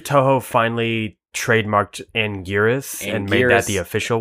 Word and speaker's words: Anguirus: Anguirus. Or Anguirus. Or Anguinus? – I don't like that one. – I hate Anguinus, but that's canon in a movie of how they pronounce it.